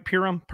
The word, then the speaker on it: Purim: But we also know that Purim, am I saying that right? Purim? – Yeah.